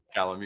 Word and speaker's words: Calame: Okay. Calum. I'll start over. – Calame, you got it.